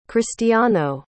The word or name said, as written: cristiano